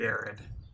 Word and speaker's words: Arad: Mr. Erad, Avi, how do you pronounce his name? – I believe it's Avi Arad.